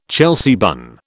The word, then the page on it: Chelsea bun: British English